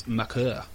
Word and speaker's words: Makur: Apparently it's not pronounced Maker. We've all been saying it incorrectly. It's Makur. – Makur. – Jeez. But apparently there's an R roll.